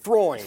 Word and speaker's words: Freund: Pronounced Freund. – Think Freud with an N.